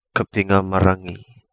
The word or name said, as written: Kapingamarangi